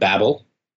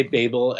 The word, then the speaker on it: Babel: Oh, are you talking about Babel? – I am. Did you change the way you pronounce it? – So I did say Babel at first, and then I was corrected by Seb.